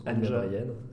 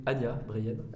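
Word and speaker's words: Anja Breien: Anya Breien. – Vas-y. – Anya Breien.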